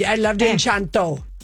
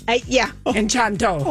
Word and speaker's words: Encanto: I loved Enchanto. – Encanto.